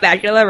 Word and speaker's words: Bakula: Did you know that Scott Bakula rhymes with Dracula? – Of course!